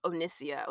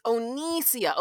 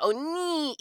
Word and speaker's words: Onnissia: So I remember Cameron was a president and he kept calling me Onnissia with like a short I, like Onnissia, Onnissia, Onnissia. And I remember one day I was like, Cameron, I like slammed my hand on the table. I was like, it's Onnissia, Onnissia.